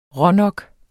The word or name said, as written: rånok